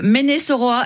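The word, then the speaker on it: Mainé-Soroa: Alors, vous nous appelez de Ménès-Oroa. Est-ce que je prononce bien ? – Oui, c'est ça.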